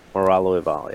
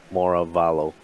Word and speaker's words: Moralo Eval: I'm a big fan of Moravalo, but I've actually never played Moralo Eval. Is that how you say it? – Moralo. – Moralo Eval, yeah. I was calling him Moravalo. That's weird.